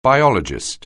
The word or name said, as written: biologist